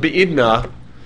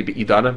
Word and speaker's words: Be'idna: Right, the principle of Be'idna, as it's usually pronounced, technically Be'idana, but we say, you don't talk that way, you say Be'idna, that's the way we say it in the base Midrash.